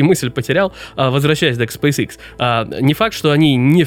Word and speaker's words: SpaceX: Сюда могут допрыгнуть игроки, например, та же SpaceX, или SpaceX, как меня иногда исправляют, но мне все же кажется, когда я говорю по-русски, говорить SpaceX абсолютно нормально.